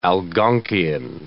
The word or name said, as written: Algonkian